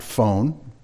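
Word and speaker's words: phone (φωνή): As best he can describe it, the voice, the word is phoné, we'd pronounce it phone, spelled the same,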